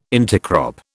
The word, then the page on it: intercrop: British English